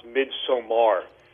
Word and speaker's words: Midsommar: Tell me about I think it's pronounced Midsummer, but it's a Swedish film. It's spelled, summer is spelled S-O-M-M-A-R. Is it pronounced Midsummer? – No, it's actually pronounced Midsommar.